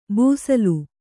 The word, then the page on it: ಬೂಸಲು: būsalu